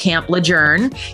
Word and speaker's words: Camp Lejeune: First of all, no one tells you that it's pronounced Camp Lejeune. You can tell usually who has been stationed at Camp Lejeune by the way they say it, because the way it's spelled, it looks like it's Camp Lejeune. And apparently it's named after someone whose name was pronounced Lejeune. And so that's why it you need to pronounce it that way.